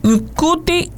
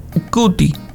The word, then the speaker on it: Ncuti: Nkuti Gadwa. Se dice así porque se escribe N-C-U-T-I. Entonces, Nkuti, porque él es como de por allá, de Guinea.